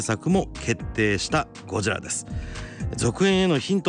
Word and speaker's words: ゴジラ: 渡辺謙のこのゴジラの発音ね、ゴジラっていうこの発音が、英語的なガジラとかねじゃなくってちゃんと日本語発音でゴジラというふうに言ってるのも、オリジナルへのリスペクトじゃないですかね。